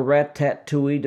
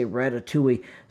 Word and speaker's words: Ratatouille: But the ones that we have in common are Ratatouille, or Rat-tat-touille, depending on how you choose to pronounce it. I say Ratatouille.